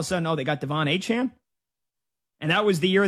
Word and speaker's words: Achane: Oh, it's Achane.